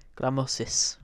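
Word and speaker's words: Grammozis: I can't pronounce his name. – Gramozis? – Gramozis, all right, yeah.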